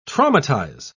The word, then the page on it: traumatise: British English